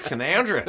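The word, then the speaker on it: Canowindra: Yeah, he was born in Canoundra, which is like a little village, and spelled Can-O-Windra. People come, we came through Can-O-Windra on the way. – No. – That's ridiculous. That's Canoundra. – That'd be Canoundra.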